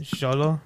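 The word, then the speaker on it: Xolo: The X is kind of like a like an S. Zolo, like that.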